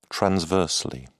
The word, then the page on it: transversely: British English